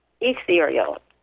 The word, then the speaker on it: ethereal: The gates are ordered like that because they're going from the most material or physical to the most ethereal, if I'm saying that right. – Mm-hmm. Yeah, that sounded good to me.